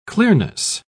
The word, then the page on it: clearness: British English